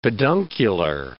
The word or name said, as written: peduncular